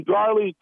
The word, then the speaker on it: Garley: Oh, my name's Cootie Garley. – How do you say your first name? – K-U-D-E-E, last name Garley, G-A-R-L-E-Y.